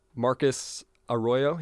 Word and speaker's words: Marcus Arroyo: And so I guess the first question I want to ask you is Marcus Arroyo. He's the coach, right? Did I pronounce that right? – Yeah, you got it right.